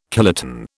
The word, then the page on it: kiloton: British English